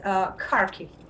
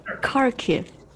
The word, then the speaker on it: Kharkiv: And you guys are from Charkiv. Is that right? Right pronunciation? Charkiv? Yeah. – You know, almost, but it's Kharkiv. – Oh, Kharkiv.